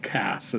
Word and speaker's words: Cass: It's spelled C-A-S-E, but I think it's pronounced Cass instead of Case.